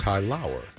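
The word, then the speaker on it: Tylaur: Whose name is spelled, by the way, T-Y-L-A-U-R. I've never seen that spelling before. – Ty Lauer.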